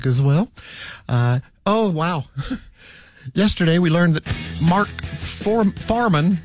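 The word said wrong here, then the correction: Fuhrman: how do you say his name? Farman? That guy from the O.J. Simpson trial. – Yeah, the cop, right. – Yeah, he died. – Mark Furman. Yeah. – Furman.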